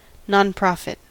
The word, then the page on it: nonprofit: American English